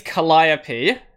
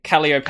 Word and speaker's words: Calliope: So it in English, it's Calliope. But if you spell it in katakana, it's Calliope.